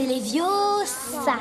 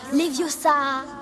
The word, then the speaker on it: Leviosa: C'est Leviosa. – Et pas Leviosa.